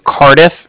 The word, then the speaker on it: Cardiff: Okay. – And then the Bank of Cardiff is spelled C-A-R. D-I as in dog, and then I-F-F as in Frank Frank. So it's like car and then different.